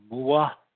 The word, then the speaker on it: moa: The moa, M O A.